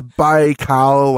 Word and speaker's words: Baikal: But I think it's Baikal, like Bai as in bicycle and Kal as in like Kal-El. Baikal.